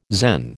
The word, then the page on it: Zen: American English